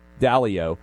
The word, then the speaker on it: Dalio: So they're actually, and then by the way, Ray Diallo, and I may be mispronouncing his name, Dalio, $75 million, $250 each for Connecticut kids.